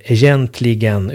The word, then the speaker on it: egentligen: Ordet egentligen i titeln stavas E-J-E-N-G-K-L-I-E-N. Eftersom vi inte säger egentligen utan egentligen.